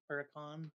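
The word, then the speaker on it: Huracán: A Lamborghini Hurricane? – Huracan. – That's actually how it's pronounced? – I guess.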